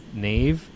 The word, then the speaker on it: Niamh: N-I-A-M-H? – Yeah, A-M-H. – Yeah. – Like, how? – What's wrong with that? What's wrong with that? H is a V.